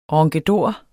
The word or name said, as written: ronkedor